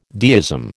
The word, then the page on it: deism: American English